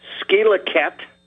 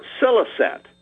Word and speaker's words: Scillet: S-C-I-L-L-E-T, and you can pronounce it skelaket or siliset.